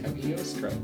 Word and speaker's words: Cagliostro: Cagliostro. – I got it right! Cagliostro!